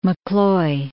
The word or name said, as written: McCloy